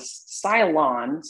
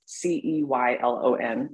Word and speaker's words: Ceylon: Yeah, I think there must be several kinds because, yeah, I've got another one in my pantry that's the Cylon. So it's Ceylon, if I'm saying that right.